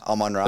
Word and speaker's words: Amon-Ra: Is it pronounced Amon-Ra? St. Brown? – Yeah, Amon-Ra.